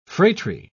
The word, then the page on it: phratry: British English